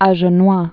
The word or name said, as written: Agenois